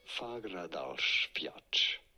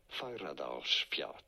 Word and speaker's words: Fagradalsfjall: In Iceland, how do you say it? Fagradalsfjall. Fagradalsfjall. – Fagradalsfjall. – Is how it is said in Icelandic, or close enough.